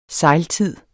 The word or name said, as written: sejltid